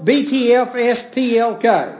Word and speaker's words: Btfsplk: and I'm going to pronounce this. This is exactly the way you're supposed to pronounce it. Joel B-T-F-S-P-L-K. That's the way you said his last name. Joel B-T-S-F-L-S-P-L-K.